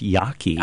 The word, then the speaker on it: Jaeggi: You look at the work, and I don't even know how to say their names, J-A-E-G-G-I. – Her name is pronounced Yaki.